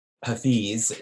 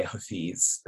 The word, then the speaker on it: Hafiz: And so Hafez or Hafiz, depending on what language you say his name in, because people argue about how you pronounce the word, but of course, from Arabic, you know, across the Silk Road from the Arab world, from the Maghreb really to Pakistan, or to China, which was really the eastern end of the Muslim world, pronunciations change. And so in Farsi, one would say Hafez. In Urdu, which is in South Asia, one would say Hafiz.